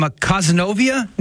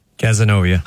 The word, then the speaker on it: Cazenovia: But from Cazenovia, New York? – Cazenovia. – I was so close. I was that close. Sebastian, he says he's the biggest Joe McLean fan from Cazenovia, New York.